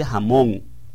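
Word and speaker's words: jamón (הָמוֹן): Pero aquí, como está escrito en el diccionario Strong, se pronuncia jamón, tal cual como el jamón.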